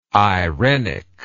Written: eirenic

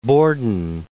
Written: Borden

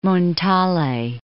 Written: Montale

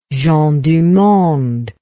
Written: gens du monde

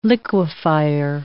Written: liquefier

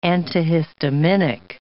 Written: antihistaminic

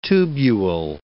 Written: tubule

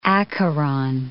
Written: Acheron

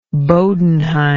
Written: Bodenheim

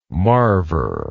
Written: marver

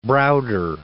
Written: Browder

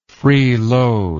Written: freeloading

